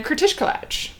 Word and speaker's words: kratiskalac: make sure you eat some langos and kratiskalac. – Langos, so, man. It's the best. – I know if Timmy's listening, she probably is cringing at how Kür. I just pronounced it. It's kratiskalac.